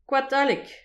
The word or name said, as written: koad haleg